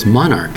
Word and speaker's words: monarch: Monarch, M-O-N-A-R-C-H. It's a K sounding for that C.